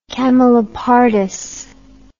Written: camelopardus

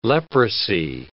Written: leprosy

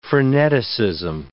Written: freneticism

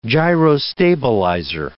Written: gyrostabilizer

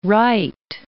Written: Rheydt

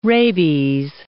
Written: rabies

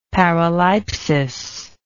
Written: paraleipsis

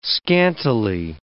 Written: scantily

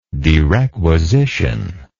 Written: derequisition

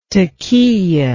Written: taqiyah

